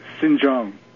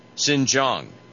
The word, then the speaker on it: Xinjiang: How do you pronounce that province? – Xinjiang. – Xinjiang. – It means basically new territories or new area. – And it's pronounced Xinjiang.